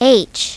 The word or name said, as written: h